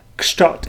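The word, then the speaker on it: Kszczot: Did I say his name right? Is that how you say it? Schott? – Yeah, Schott, yeah.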